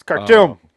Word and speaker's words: Khartoum: It's Khartoum.